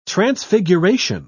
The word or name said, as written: transfiguration